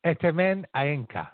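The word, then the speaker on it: Etemen Ænka: I got a pronunciation here. Etemen Ayaneka. I got it.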